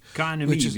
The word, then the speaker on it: KNMI: But what I was reading the other day was, I believe, K-N-M-I. – K-N-M-I. – K-N-M-I, we say in Holland. K-N-M-I. – Say it again? – K-N-M-I. The I we pronounce as an E. So not to subtract or to change with the E, the echo.